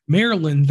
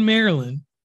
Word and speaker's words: Maryland: I've been pronouncing it Maryland all these years, so I you know people call it Merlin. – Yeah, you just heard me say it.